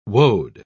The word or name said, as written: woad